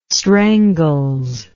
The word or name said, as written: strangles